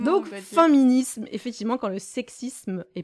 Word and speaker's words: Faiminisme: Donc, ça s'écrit F-A-I-M-I-N-I-S-M-E, et les gens me disent ça se prononce féminisme ou féminisme. Je suis là, maintenant je dis comme vous voulez. Mais encore une fois, on a faim, donc féminisme.